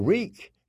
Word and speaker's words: Reek: "You are no prince, you're Reek, just Reek, it rhymes with freak.